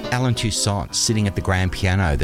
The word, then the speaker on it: Toussaint: And in case you were wondering, I'm pronouncing his name Toussaint because that's the way he pronounced it. It's spelt T-O-U-S-S-A-I-N-T, but because of the French influence in New Orleans, it's pronounced Toussaint.